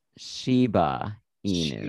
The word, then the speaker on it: Shiba Inu: And last week, he thought it was a Shina Ibu. – I mispronounced the dog, because it's a similar dog breed to. – Yes. – And it's actually pronounced Shiba Inu.